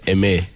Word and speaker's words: Aimé: Aimé. – Aimé. – Yeah, it's French.